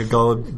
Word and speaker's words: Ghalib: No, but there's a quote from Ghalib. Is that how you say it? – Yeah, Ghalib.